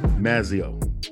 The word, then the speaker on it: Mazzeo: Mazzeo.